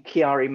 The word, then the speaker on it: Chiari: Okay, I think you're probably referring to syringomyelia and the related condition, Chiari malformation.